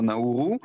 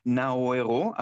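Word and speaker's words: Nauru: השם בדרך כלל, השם עד כה היה נהוג לבטא אותו נאורו, עכשיו הם טענו שזה הצורה הלא נכונה לבטא אותו, ולמעשה בהגייה הנכונה היא נאוארו.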